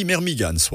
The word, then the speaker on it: Mermigans: Des fois, on ne sait pas trop bien comment prononcer, mais il paraît qu'on dit Mermigans. Voilà. – À l'anglaise.